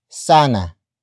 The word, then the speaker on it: sana: It features the word sana.